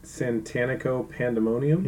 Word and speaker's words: Santanico Pandemonium: Santanico Pandemonium.